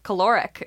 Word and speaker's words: caloric: And then when food was scarce and starvation was a real threat, large fatty breasts suggested caloric is how you pronounce that word.